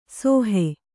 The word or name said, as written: ಸೋಹೆ